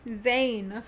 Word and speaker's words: Zayn: Zayn.